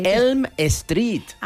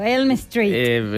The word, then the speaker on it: Elm Street: Que no se dice así. – ¿Y cómo se dice? – Elm Street. – Ah, bueno, es que yo lo he españolizado, Elm Street. – Es que se escribe igual, ¿eh?